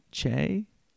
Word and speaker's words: Che: Is it Shay? Che? – Yes, perfect. Amazing. Perfect.